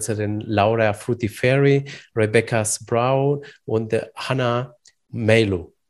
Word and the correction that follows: Mailu: Mailu. Ich hoffe, ich habe es richtig Melu, ausgesprochen. Ja, Melu. Melu, genau, genau.